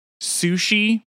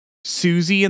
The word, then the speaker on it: Siouxsie: Fear of the Unknown is a song by Susie and the Banshees. Sushi. It's spelled S-I-O-U-X-S-I-E. Sushi and the Banshees. – I have no idea. – Susie and the Banshees.